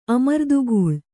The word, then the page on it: ಅಮರ್ದುಗೂಳ್: amardugūḷ